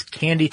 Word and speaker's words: Kandi: And you know what's really clever about this is that it's Candy Technologies, spelled K-A-N-D-I, but pronounced candy, and they call it the candy machine.